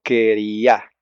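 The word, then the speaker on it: quería (קריעה): Se dirigieron ellos a toda la congregación de los hijos de Israel, diciendo, y lo llaman en hebreo la quería, ¿saben? La quería, a eso de romperse la ropa, de hacer una rasgadura en la vestimenta, le llaman quería, con acento en la A.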